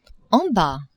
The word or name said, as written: en bas